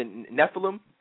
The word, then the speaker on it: Nephilim: You mentioned, am I pronouncing it, the Nephilim? – Right. Mm-hmm.